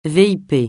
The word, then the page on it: VIP: francais